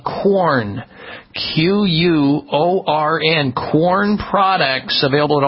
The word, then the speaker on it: Quorn: Quorn, it's kind of a strange name. It sounds like, and you think corn, but it's spelled with a Q. Q-U-O-R-N products are meatless.